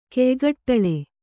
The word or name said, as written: ಕೆಯ್ಗಟ್ಟಳೆ